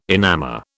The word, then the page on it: enamour: British English